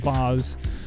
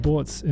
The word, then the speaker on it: Boz: And I was like, Boz, but spelled B-O-Z. And he was like, oh, Boitz, Boitz. I guess that's how it's pronounced. B-O-Z is pronounced Boitz in German.